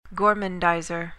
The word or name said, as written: gormandizer